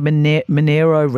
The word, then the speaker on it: Monaro: and that is the Monaro. I think they pronounce it their Monero region of southern New South Wales.